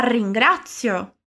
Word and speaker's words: ringrazio: Então, mais uma vez, mas agora prestando atenção na pronúncia do R, que é sempre mais vibrante. La ringrazio.